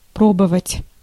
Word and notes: essayer: [e.se.je]; /e.sɛ.je/; /ɛ.sɛ.je/

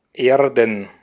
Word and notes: Erden: /ˈeːɐ̯dn/

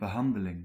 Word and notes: behandeling: [bə.ɦɑn.də.liŋ]

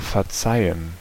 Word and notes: excuse: American English

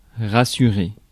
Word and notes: rassurer: [ʁa.sy.ʁe]; France

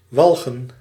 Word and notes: disgust: [dɪs.ˈgʌst]; /dɪs.ˈɡʌst/; /dɪsˈkʌst/

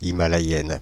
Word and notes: himalayenne: /i.ma.la.jɛn/

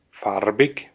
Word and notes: farbig: /ˈfaʁ.bɪç/; [ˈfaɐ̯bɪç]; German

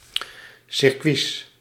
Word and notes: circuits: /sɪrˈkʋis/

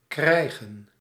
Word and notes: krijgen: /ˈkrɛi̯.ɣə(n)/; /kɾɛj.ɣǝ:/

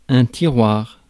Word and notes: tray: /treɪ/

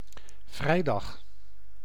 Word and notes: vendredi: [vɑ̃.dʁə.ˈdi]

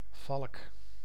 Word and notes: faucon: France; Paris; [ɛ̃ fo.kɔ̃]

